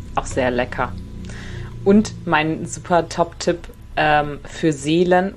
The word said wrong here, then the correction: Selen: oder wie wird das ausgesprochen? – Selen? Für die Seelen.